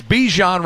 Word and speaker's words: Bijan: Right, and Bijan. – And we know he has that with the kids. – It's Bijan Robinson, but his mother pronounced it Bijan.